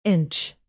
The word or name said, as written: inch